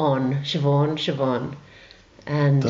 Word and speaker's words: Siobhan: It's pronounced Siobhan. – Siobhan. – Why? – Why? The S and the I is a sh, yeah. – And the B and the H is a v.